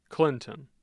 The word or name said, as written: clinton